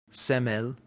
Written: semel

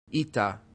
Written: ita

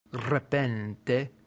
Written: repente

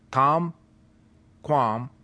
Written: tamquam

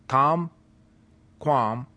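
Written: tam ... quam

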